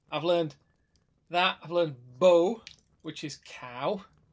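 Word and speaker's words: bó: Bo is cow with a little accent on it. So it's a long O.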